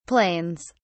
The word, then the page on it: planes: British English